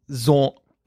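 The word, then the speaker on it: Xorn: It's X-O-R-N. So we've been calling him Vaughn, but it's Zorn.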